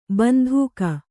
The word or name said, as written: ಬಂದೂಕು